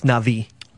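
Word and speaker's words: Na'vi: It's pronounced Navi.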